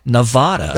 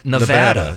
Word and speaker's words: Nevada: But the next toughest state to pronounce was Nevada because people can't stop saying Nevada. – That should have been over Arkansas, I think. – Instead of the preferred Nevada. – Nevada. Yeah. – What do you say? Nevada, Nevada? – I don't know.